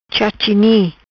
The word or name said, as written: Chachini